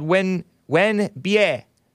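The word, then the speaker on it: Wenbie (吻别): This track is called Wen Bie. Let me just double check the pronunciation, because in the pinyin it matters.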